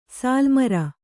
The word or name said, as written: ಸಾಲುಮರ